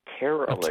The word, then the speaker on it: pterologist: Pterologist. – A pterologist with a silent P.